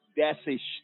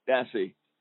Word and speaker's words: Dassey: And the Brendan Dassey story. Right now joining us is Tracy Keogh, writer for World Vision Australia and a creative activist and marketer for Brendan Dassey.